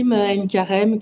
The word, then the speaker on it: Aïn Karim: Oui, Aïn Karim, qui est situé à proximité de Jérusalem, d'ailleurs.